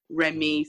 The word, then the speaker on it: Rehmee: So I decided to spell it how it sounded, Remy. So, like, I wouldn't be like, oh, it's pronounced, it's actually this way. So it's a lot easier. It's Remy and Coco, but the name Remy stems from the angel in heaven that looks after the babies, essentially.